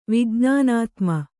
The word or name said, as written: ವಿಜ್ಞಾನಾತ್ಮ